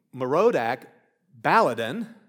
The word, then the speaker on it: Merodach Baladan: At that time, Merodach Baladan, that's how you say it,